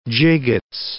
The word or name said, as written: gigots